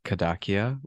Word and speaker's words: Kadakia: Yeah, so my first name is Kushal, which is spelled K-U-S-H-A-L. My last name is Kadakia, which is K-A-D-A-K-I-A, and you can just call me Kushal.